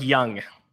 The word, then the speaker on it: Jung: Justin says pronounced like Young. So it is, yeah, Young.